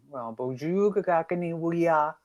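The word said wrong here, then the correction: gakina awiiya: I said that wrong. It's gakina wia. Bonjour, gakina wia.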